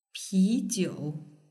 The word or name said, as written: pijiu